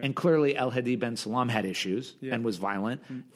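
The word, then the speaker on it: El Hedi ben Salem: Can you just give me his name so I can say it right? – El Hedi Ben Salam.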